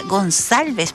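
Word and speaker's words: Gonçalves: Y él decía: no, mi apellido va con una cedilla abajo y se pronuncia Gonsalves, porque mi apellido es portugués, dice el caballero.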